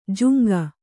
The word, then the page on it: ಜುಂಗ: kannada